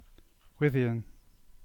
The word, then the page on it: Gwithian: English